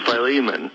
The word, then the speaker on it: Philemon: Some say Philemon, some say Philemon, or some have said something that I couldn't identify. What's the correct pronunciation? – Well, I believe the correct pronunciation is Philemon.